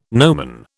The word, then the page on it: nomen: British English